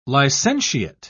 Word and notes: licentiate: British English

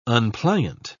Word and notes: unpliant: British English

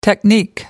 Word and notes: technique: American English

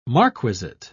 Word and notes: marquisate: British English

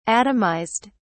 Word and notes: atomised: British English

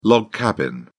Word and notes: log cabin: British English

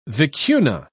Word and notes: vicuna: British English

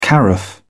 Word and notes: carafe: British English

